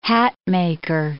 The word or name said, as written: hatmaker